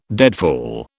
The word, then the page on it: deadfall: British English